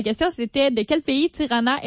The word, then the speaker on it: Tirana: Non, Albanie, en fait, Tirana, qui est prononcée en albanais Tirana, est la ville la plus peuplée d'Albanie.